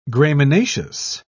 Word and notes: graminaceous: British English

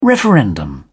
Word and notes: referendum: British English

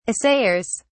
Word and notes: assayers: British English

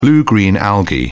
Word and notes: blue-green algae: British English

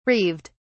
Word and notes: reeved: British English

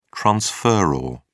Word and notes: transferor: British English

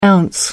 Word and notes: ounce: American English

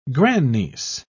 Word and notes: grandniece: British English

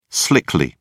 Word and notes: slickly: British English

